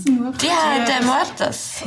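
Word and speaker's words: Día de Muertos: Dia de Muertos. – Ja, genau, Tag der Toten. Dia de Muertos.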